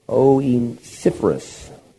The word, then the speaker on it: Onesiphorus: The Lord give mercy unto the house of Onesiphorus. That's really how it's pronounced in mine anyways.